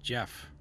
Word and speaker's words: Gef: Now, I want to say this is pronounced Jeff. – All right. – Fucking Jeff. – Oh, it's got to be a fucking Jeff. – It says the story of Jeff, G-E-F, comma, or the Dalby Spook.